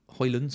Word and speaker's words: Højlund: FPL Prasim says, if Rasmus Højlund is 7... Højlund, sorry, didn't pronounce the D, is 7.5.